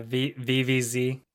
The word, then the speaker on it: Viviz: Viviz, how do you pronounce their name? – Yeah, Viviz.